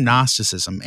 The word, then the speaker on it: Gnosticism: Well, the term Gnosticism, and you can't hear it, but there's a silent G at the beginning, really comes from the Greek word gnosis, which means knowledge.